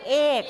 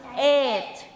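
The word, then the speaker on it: eight: เราจะออกเสียงว่าเอกซึ่งเหมือนกับคำว่าไข่ซึ่งไม่ใช่ต้องออกเสียงว่าเอทไม่ใช่เสียงกอไก่นะคะเอทนะเอทไม่ใช่เอก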